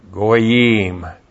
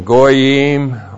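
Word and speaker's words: goyim (גוים): And then the goyim. G long O Y I M, goyim. And the goyim are the nations, the Gentiles, all the non-Jewish people.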